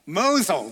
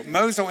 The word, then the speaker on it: Mosul: Mosul. – There you go. Mosul in Iraq.